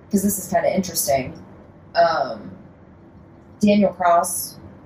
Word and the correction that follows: Kraus: is... Kraus, I think, is probably the right pronunciation. It's K-R-A-U-S.